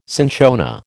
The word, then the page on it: cinchona: American English